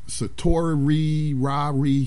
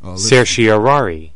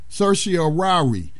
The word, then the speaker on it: certiorari: Okay, satori rari, something like that. I can't pronounce that. – Certiorari. – Okay, there, we just got it. – Certiorari. – Certiorari is how you pronounce that.